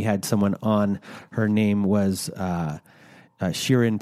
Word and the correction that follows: Shireen: Pekar, and uh, sorry, I didn't say her name properly. Shireen uh, Pekar and Shireen was.